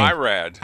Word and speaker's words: Irad: Is it Idna? Idna? How do you pronounce his name? – No, Irad. – Irad, that's it.